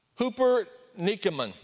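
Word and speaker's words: hupernikemon (ὑπερνικῶμεν): It's pronounced in English, hupernikemon, which has the word Nike in it.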